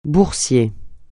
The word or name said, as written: boursier